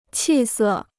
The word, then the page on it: 气色: qì sè